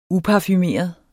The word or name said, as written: UPARFUMERET